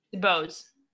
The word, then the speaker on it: DuBose: How do you say Phoenix's last name? – DuBose. – It is DuBose. Okay. DuBose. We didn't know if you went with the French, you know, Dubois.